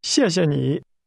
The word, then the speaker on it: 谢谢你: In that, the word for you is pronounced with a falling, rising tone. Say again, thank you. – 谢谢你。